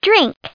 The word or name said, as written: drink